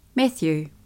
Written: Matthew

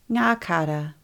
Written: Nga kara

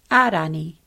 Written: Arani

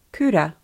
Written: kura

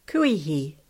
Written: Kuihi

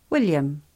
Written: william

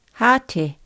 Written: hate

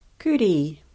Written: Kuri

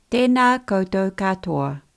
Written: tena koutou katoa

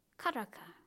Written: karaka

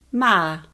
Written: ma

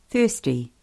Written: hiainu

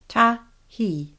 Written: tahi

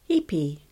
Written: Hipi

